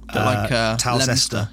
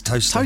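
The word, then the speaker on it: Towcester: And I take it it's spelt odd as well. Talcester. Yeah, Talcester. – Yeah, but it's toaster.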